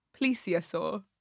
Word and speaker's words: plesiosaur: It's a plesiosaur, not a plesiosaur. How do you say it? – Plesiosaur.